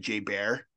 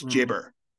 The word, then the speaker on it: Jayber: So it's Jayber, like burr, not bear. – B-E-R. – B-E-R.